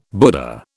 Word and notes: Buddha: British English

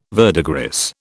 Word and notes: verdigris: British English

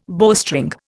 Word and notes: bowstring: Indian

